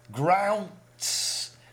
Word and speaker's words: Grounts: Grounds? – Grounts. It's spelled G-R-O-U-N-T-S.